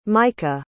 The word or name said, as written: micah